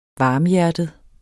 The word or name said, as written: varmhjertet